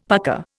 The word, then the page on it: pucker: Indian